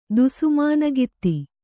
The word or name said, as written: ದುಸುಮಾನಗಿತ್ತಿ